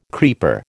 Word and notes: creeper: American English

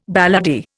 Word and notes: balladeer: Indian

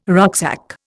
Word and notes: rucksack: Indian

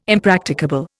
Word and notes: impracticable: Indian

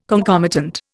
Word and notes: concomitant: Indian

